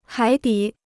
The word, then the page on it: 海底: hǎi dǐ